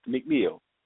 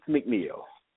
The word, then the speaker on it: McNeill: The Most Fun Speaker, or just by my name, Brian Keith McNeil. You can also find me on Facebook. On Facebook, my name is Brian Keith McNeil. Brian Keith McNeil on Facebook. McNeil is spelled M-C-N-E-I-L-L.